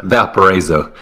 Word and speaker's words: Valparaiso: Uh, I'm uh, seeing here that uh, we're going to be going to play Indiana, and we played uh, Valspro. How do you say that? How's that? – Valparaiso. – Valparaiso.